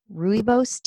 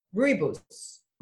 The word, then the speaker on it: rooibos: And I've actually recently, my mother-in-law got me hooked on, I don't know if I'm going to pronounce it right, rooibos tea, R-O-O. Yeah, how do you say it? – Rooibos. – Rooibos. Okay.